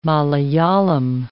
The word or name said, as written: Malayalam